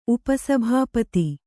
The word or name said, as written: ಉಪಸಭಾಪತಿ